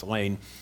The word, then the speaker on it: Leen: Lane Rittmeyer, L-E-E-N, pronounced Lane.